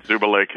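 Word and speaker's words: Zubulake: Am I pronouncing that right? I should know it by now. – Zuba Lake is the pronunciation.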